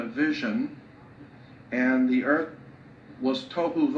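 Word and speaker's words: tohu (תהו): Pronunciation, it would be T-O-E hyphen W-H-O, tohu. That's not the way it's spelled in Hebrew, but that's the way it's pronounced. T-O-E W-H-O, tohu. Vohu would be V-O-E W-H-O, tohu, vohu, waste, desolation.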